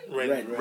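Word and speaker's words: Rennes: He started his career at, I'm not sure how you pronounce this. Is it Ren? Ren? – Ren.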